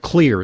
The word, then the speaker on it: Xlear: It's called Clear, it's spelled X-L-E-A-R.